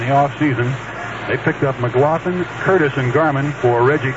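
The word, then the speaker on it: McGlothen: This pairing tonight with a left-hander Rooker and a right-hander Lynn McLaughlin. That's M-C-G-L-O-T-H-E-N, and it's pronounced McLaughlin, Lynn McLaughlin.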